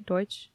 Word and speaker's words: Deutsch: I think I said that right. Did I say that right? Deutsch? – Mm-hmm. Deutsch mark notes? – Yeah. This was the official currency in Germany at the time in 1970.